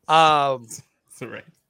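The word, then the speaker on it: Saraya: They've corrected us. – Please say it correctly. – Soraya.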